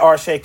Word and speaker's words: Arshay: Yes, at Arshay Cooper on Instagram and on Twitter. That simple. – There it is. Arshay, A-R-S-H-A-Y.